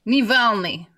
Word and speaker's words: Navalny: Did I say his name right? Is it Navalny? – Navalny. – I knew how to say it.